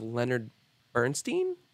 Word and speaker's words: Leonard Bernstein: So because I'm crazy and I love knowing everything, his next project that he's directing and starring in is a biopic of Leonard Bernstein.